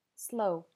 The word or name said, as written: slow